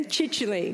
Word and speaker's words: Chichele: the Chichele Professorship. Was that right, Amia? We That's it. Put, we talked about this before, and it was rhymes with bitchily. yeah? Chichely. That's, that's what Philip Larkin used to say.